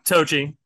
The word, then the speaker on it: Toji: Is it Toji? Is is that how you pronounce it? – Yeah, Toji.